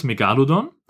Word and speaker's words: Megalodon: Nämlich einmal so, wie es richtig ist, Megalodon.